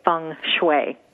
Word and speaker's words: Feng Shui: And for those who might want to know that specifically, it is two separate words, and it is spelled F-E-N-G, as in Feng, but it is pronounced Feng. And then the separate word is S-H-U-I, and that's pronounced Shui, Feng Shui.